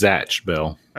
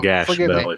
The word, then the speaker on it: Zatch Bell: Is that how it's supposed to be pronounced, or did I say it wrong? Zatch Bell, right? – Yeah, it's Zatch Bell. – Okay. – Or Gash Bell, if you're reading it.